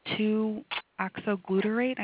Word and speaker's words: 2-oxoglutarate: but his levels in the organic acid for 2-oxoglutarate,